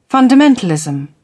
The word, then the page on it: fundamentalism: British English